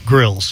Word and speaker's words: Grylls: How do you say his last name? – Grylls. – Grylls. – Just like what you would do outside.